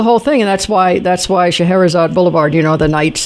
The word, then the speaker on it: Scheherazade: Well, I know one address you'll never forget, and that's Alibaba Avenue and Scheherazade Boulevard. – Scheherazade Boulevard. – Sh- Shahrazad? – Yeah, right. It's Shahrazad is how it's pronounced.